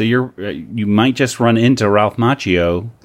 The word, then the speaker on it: Ralph Macchio: It was also, as Kelly mentioned before the show started, Ralph Macchiato's? How do you pronounce his name? – Ralph Macchio.